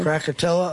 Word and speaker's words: Krakatoa: The one that had the bigger global impact was, Krakatoa? Is that how you say it? – Krakatoa? Oh, yeah.